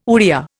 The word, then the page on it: Oriya: Indian